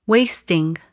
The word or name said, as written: wasting